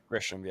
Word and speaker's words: Grisham: And Trent Grissom. How do you say his name? Trent Grisham? – Grisham, yeah.